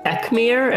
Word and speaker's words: Ekmir: And that companion is Ekmir.